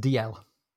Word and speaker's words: Dalziel: I don't know about intelligence, but it's a name that I would have pronounced DL. There have been a few famous DLs. It is an anglicization of a Scots Gaelic name.